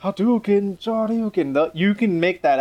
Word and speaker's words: Hadouken: Hadouken. Hadouken. You just kind of skip the ha. It's just like it's like in Spanish, the H is silent.